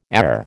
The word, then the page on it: err: American English